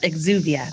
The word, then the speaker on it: exuvia: An exuvia. Did I say that right? – Yep, exuvia.